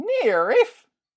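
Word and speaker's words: NIIRYF: Those are letters for Not If I Beat You First, and they're pronounced NIRIF!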